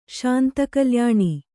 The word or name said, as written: ಶಾಂತಕಲ್ಯಾಣಿ